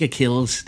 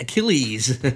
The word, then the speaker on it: Achilles: Det er ikke Achilles, det er Achilles.